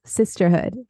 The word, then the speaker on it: Cysterhood: We also have our Instagram account, pcos.weightloss, and our app that just launched called Sisterhood, The Sisterhood. It's spelled CYSTERHOOD.